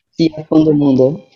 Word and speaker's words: Dia Fim do Mundo: With your circus, and I want to make sure I pronounce it correctly, is Chia Fundo Mundo. Is that correct? – Dia Fim do Mundo.